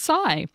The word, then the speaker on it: SY3: S-Y-3. But then if you look it up on the internet, you can get educated on how that word is pronounced, and it's pronounced Psy.